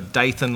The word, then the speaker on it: Dathan: So Dathan like Nathan, like Fanthas like Bantha.